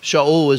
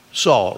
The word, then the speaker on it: Shaul: His name is Shaul. Shaul is the Hebrew pronunciation. He's named for an Old Testament king, Shaul. Saul, right?